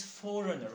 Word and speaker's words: foreigner: And the pronunciation is foreigner, okay? It is not foreigner. It is foreigner. – Foreigner. – The g is silent.